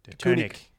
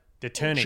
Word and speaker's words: Deceuninck: The Tunic. – Giant. I thought it was King Quickstep. – There's no T. – Tunic. The Tunic. – DECEUNINCK. – It's as close as I can get in Flemish.